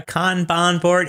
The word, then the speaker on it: Kanban: And then I put those into the GitHub like Kanban board. I don't know. You know that thing where it's like Trello where you drag and drop into different columns? Is that called Kanban board? – Yeah, Kanban board.